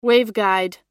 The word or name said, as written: waveguide